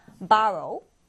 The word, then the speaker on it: borrow: O 발음이 아니라 A 발음으로 borrow, borrow 이렇게 읽어줍니다.